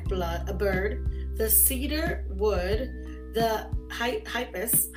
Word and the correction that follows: hyssop: hy- hip, I even ask how to pronounce this. Hyssop. Hyssop. That's what it is.